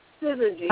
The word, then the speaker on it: Syzygy: This is, is it the Syzygy? – Syzygy, yes.